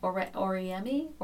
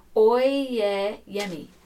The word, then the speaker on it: Oyeyemi: So I'm reading this book right now by Helen Oriemi. Oriemi? How do you say her name? Hold on a second. I've got it written down. This is the other reason I wrote things down. Oyeyemi.